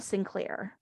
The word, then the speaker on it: St. Clair: And then for those who have been reading this book and maybe haven't heard it pronounced, when you read it, it looks like the child's name is St. Clair, like S-T period Clair, which is even more ridiculously over the top and pompous, right?